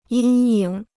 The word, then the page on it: 阴影: yīn yǐng